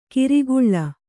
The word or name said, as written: ಕಿರಿಗುಳ್ಳ